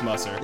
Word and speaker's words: Musser: I get a lot of Musser, but it's Musser.